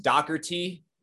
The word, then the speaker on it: Dougherty: My last name is pronounced Dougherty, Docker and T. So I guess it's the D-O-U-G-H would come off super harsh. Like the G-H would almost be like a C-K, but I don't know.